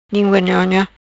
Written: Lingua Nhonha

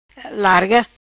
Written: Larga

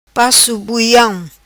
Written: paco buiam

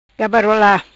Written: Gabarola

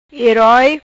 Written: Hiroi

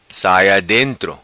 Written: Saia Dentro

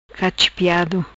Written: Cachipiado

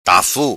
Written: Tafu

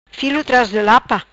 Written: Filo Tras di Lapa

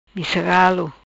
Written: Missa Galo

